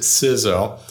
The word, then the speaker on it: CISO: I pronounce it CISO.